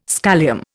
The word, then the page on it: scholium: Indian